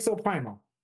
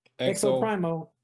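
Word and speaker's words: Exoprimal: I think I'm saying that. Exoprimo. Exoprimo. – Yeah, that's what it's called. – Yeah, it's, cool. It's dropping. I said that wrong? – No. I think you said it right.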